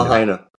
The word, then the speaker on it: Lahaina: But before we go there, I've got to ask you about Hawaii and about, is it pronounced Lahaina or Lahaina? – Lahaina.